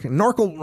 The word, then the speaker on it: NORCL: NORCL rhymes with snorkel.